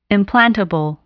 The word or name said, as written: implantable